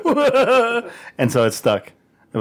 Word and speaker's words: Mijo: Basically, in Spanish, we say it right. It's Mijo, which means son.